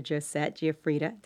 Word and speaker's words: Josette Guifridda: So my guest today is Dr. Josette Guifridda.